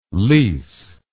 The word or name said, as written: lisse